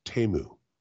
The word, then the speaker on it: Temu: It's pronounced Temu.